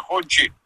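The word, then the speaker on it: Hongqi: Это пишется ханга чиганга, но читается хончи.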